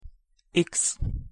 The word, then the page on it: x: Hungarian